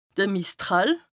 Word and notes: de Mistral: Bas-Rhin; Strasbourg

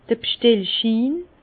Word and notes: d'r B'stellschin: Alsatian; Bas-Rhin; Herrlisheim

